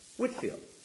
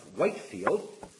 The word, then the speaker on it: Whitefield: The Christians in Maine were very shocked to find that the town of Whitefield was named for George Whitefield. It was the difference of pronunciation that threw them, the fact that it's pronounced like the color white, whereas his name is pronounced Whitefield.